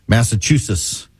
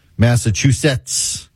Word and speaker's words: Massachusetts: I had a listener a little while ago text me and said I needed to make sure I pronounced the T's in Massachusetts. Massachusetts. Massachusetts.